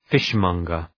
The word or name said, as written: fishmonger